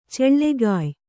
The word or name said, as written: ಚಲ್ಲಗಾಯ್